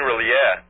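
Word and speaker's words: R'lyeh: What is Ryla? – You mean Rilea.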